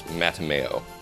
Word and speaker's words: Mattimeo: And this month we read the third book in the Redwall series in publication order, Mattimeo, which I have it on good authority. That's how it's supposed to be pronounced. I was saying it differently in my head, but apparently it's Mattimeo.